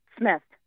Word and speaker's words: Smith: Smith.